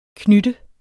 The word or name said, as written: knytte